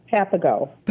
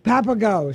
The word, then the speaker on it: Papago: Now, I'm not going to pronounce it right. I just know. Papago Golf Club? – Papago. – Papago. See, I know it's the Papago Golf Club.